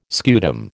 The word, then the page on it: scutum: American English